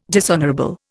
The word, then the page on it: dishonourable: Indian